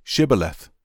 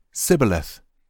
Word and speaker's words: Shibboleth: If the man said, No, I'm not, they would tell him to say Shibboleth. If he was from Ephraim, he would say Sibboleth, because people from Ephraim cannot pronounce the word correctly.